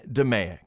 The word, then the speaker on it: DMAIC: It can also be pronounced as an acronym by saying DMAIC.